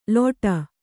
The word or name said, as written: ಲೋಟ